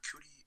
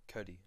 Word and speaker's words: Cudi: We're on a Cootie. Is that Cootie? Is that how you say it? Cootie? – Oh, Cuddy. Like Kid Cudi.